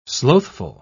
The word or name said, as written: slothful